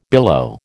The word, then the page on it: billow: American English